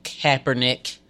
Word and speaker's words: Kaepernick: Let's just come together, because what Nike and Kaepernick, I feel like I'm saying it right.